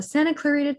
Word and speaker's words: Santa Clarita: The Santa Clarita Diet.